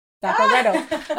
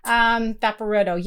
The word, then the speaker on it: vaporetto: Vaporetto. Oh, yay, I said it. – You said it right. – Right, because I usually like to say things wrong. Vaporetto, yeah.